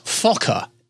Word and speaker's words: Fokker: Biggles made his first appearance in the short story The White, I have to pronounce this very carefully, The White Fokker, F-O-K-K-E-R.